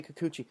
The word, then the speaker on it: Kikuchi: You say Kikuchi? – Yeah, you say okay. You say Kikuchi.